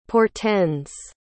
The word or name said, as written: portends